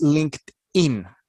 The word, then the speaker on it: LinkedIn: La correcta pronunciación es LinkedIn o LinkedIn con una T, porque son dos palabras en inglés. Link y luego la ED que viene pegada se pronuncia como una T después de la K en inglés. Entonces esa partecita se dice linked y luego lo pega uno con el in, entonces suena LinkedIn. Así se pronuncia originalmente en el idioma inglés.